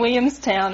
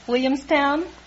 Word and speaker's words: Williamstown: Williamstown. – What happened to the L's? You knocked the L's out of that word. Can't you say Williamstown? Try it.